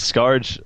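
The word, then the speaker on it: Skaarj: Yeah, my personal one that I've settled on lately, it's my World of Warcraft name, is Scarjonic, because Scarge, as you may or may not know, well, it's actually pronounced Scar, but the J is not silent to me, is a a monster in Unreal that's just, you know, all different kinds of badass.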